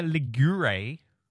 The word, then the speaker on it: Ligure: Ian says, listening from Santa Margherita Ligure. I'm going with the pronouncing all the letters in an Italian name. I think that's right.